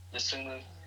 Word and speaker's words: Dosunmu: Dosunmu. – And Ayo Dosunmu. Those are the, like, literally the rankings go like this. Luca Garza, Dosunmu, and Cade Cunningham. And I may switch. I mean, Cunningham and Dosunmu are interchangeable.